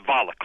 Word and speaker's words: Valick: And it's Valick, rhymes with Pollock.